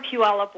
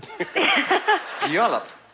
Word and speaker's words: Puyallup: Hi, I'm from Puyallup, Washington. – Yeah. – Puyallup. – Puyallup, yes. – Okay, fine. I'll let that go completely. – It took me 20 years to learn how to pronounce it.